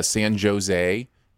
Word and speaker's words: San Jose: Like he would talk about, he was going to San Jose and somebody said, well, you don't pronounce the J in San Jose.